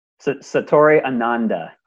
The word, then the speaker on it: Satori Ananda: How do I say that? Is that the right? – Satori Ananda. – Ananda, yeah, I mean, cool.